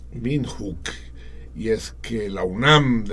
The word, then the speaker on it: Windhoek: Eh, el campus de la UNAM más cercano a, Sudáfrica es el que se encuentra en Windhoek. No sé si pronuncia Windhoek o Windhoek. Debe ser más bien Windhoek. luego pues Windhoek. – Windhoek. – Hook. Hook.